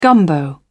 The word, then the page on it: gumbo: British English